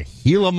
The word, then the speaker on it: Gila: It turns out it is a Gila monster and you don't have a hard G on that one. It's a Gila monster, but I believe they are venomous, therefore do not make good pets.